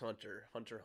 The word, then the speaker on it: Hunter x Hunter: It's Hunter x Hunter. Hunter Hunter. Sorry, not supposed to pronounce the X.